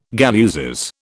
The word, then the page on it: galluses: British English